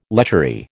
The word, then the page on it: lechery: American English